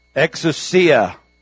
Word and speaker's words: exousia: Exousia. It's actually exousia is how you say it.